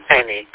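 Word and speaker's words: Alnwick: Annick is pronounced, but that's the way it's spelled, Ornwick.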